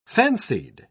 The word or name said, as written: fancied